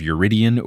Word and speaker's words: Eurytion: Now, as far as things in this episode, I really poorly butchered the pronunciation of Eurytion or Eurytion. I kept acting like the U was after the R, which it wasn't,